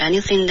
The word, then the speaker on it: anything: You really think this Mark doesn't want anything in exchange for helping you? – Okay, anything 的这个别动舌尖，所以这个是一个鼻音后鼻音的发音。 Anything, 不是靠舌尖的呢。